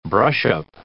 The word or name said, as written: brushup